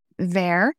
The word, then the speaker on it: verre: So it's much believed by fashion historians that that word in French, because this is a French tale, verre, V-E-R-R-E, which is a word for glass, is the same word as verre, V-A-I-R, or same pronunciation as verre, which means fur.